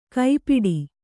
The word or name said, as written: ಕೈಪಿಡಿ